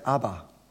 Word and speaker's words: Abba: Now, we pronounce it Abba, okay? I think that may be the influence of a Swedish pop group. I'm not sure. But it's interesting how different people pronounce it. In Urdu, although it's spelt the same way, it's pronounced Abba.